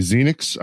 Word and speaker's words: Xenyx: This is a Behringer, if I'm saying it correctly, Xenix. I believe that's how you pronounce X-E-N-Y-X 1002.